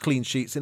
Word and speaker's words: clean sheet: The phrase clean sheet uses the E sound in both words. This is a long vowel, E.